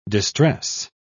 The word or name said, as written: distress